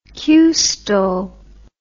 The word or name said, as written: qstol